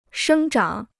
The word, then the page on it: 生长: shēng zhǎng